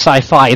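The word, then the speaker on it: Syfy: But it's still pronounced Sci-Fi.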